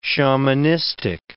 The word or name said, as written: shamanistic